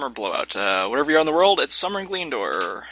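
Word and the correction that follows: Gielinor: How do you say that? – Gelinor. – Gelinor. Let's go with that.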